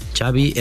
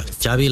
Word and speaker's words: Xavi: Xavi Hernández. – Xavi. – Okay. Yeah, Xavi like chocolate.